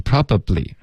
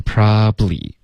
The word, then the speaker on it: probably: OK， probably 不要发成 probably， OK，用它的这个缩写的发音 probably。 OK，两个音节就够了。